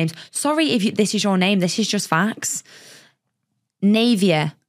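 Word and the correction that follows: Nevaeh: Oh, no, Nevea. It's heaven backwards.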